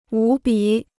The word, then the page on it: 无比: wú bǐ